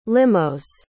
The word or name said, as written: limos